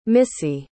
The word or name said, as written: missie